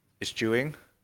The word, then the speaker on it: eschewing: Yeah, I think the overview is primarily that there's an incoming generation of investors, high net worth individuals who are now, I always want to use this word, but I'm always afraid I'm going to say it wrong, but eschewing. – That's correct.